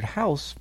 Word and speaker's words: Haus: Is it H-A-U-S House? It is indeed, yes, with the Viennese German.